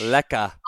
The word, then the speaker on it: Lech: Is that, it is Lech, right? – Yeah, you're saying it right. Like, good on you, because most people butcher that Lech. And call me like a leech or a lush.